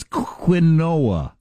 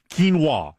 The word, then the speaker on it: quinoa: What's quinoa? – Quinoa is how you've heard it pronounced. You've probably never seen it spelled before.